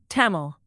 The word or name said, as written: Tamil